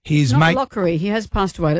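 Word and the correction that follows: Locorriere: Let's say his name correctly, she said, looking for the piece of paper. Lockerier.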